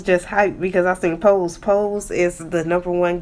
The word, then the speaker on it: Pose: That's how you pronounce it, Pose. I thought it was Posse. – Oh no, Pose.